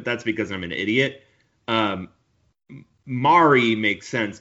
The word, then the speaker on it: Mari: So her name is Anna, but it's A-N-A. And then it's M-A-R-I. It's pronounced like calamari. Mari.